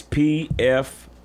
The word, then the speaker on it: Pfizer: and I think I'm pronouncing it right, but it's called Pfizer. It's Pfizer.